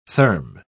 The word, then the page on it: therm-: British English